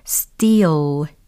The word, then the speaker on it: steal: 일단 발음 먼저 점검을 해볼까요? steal, steal, steal. 이렇게 하시면 되겠죠. 예, 좀 장음화 됩니다. 왜냐면 이이 e a라는 모음 부분이 장음이 되기 때문이에요.